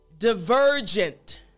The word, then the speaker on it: divergent: So after me, divergent. Good. Last time, divergent. Excellent. So this is how you pronounce the word properly, making sure your front teeth are on your bottom lip for the V sound.